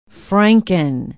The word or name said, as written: Frankin